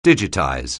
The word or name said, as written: digitize